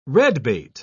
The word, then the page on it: redbait: British English